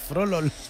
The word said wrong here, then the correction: Froholt: Froholt. – Está muy bien pronunciado. – Froholt. – Pero es que la H intercalada.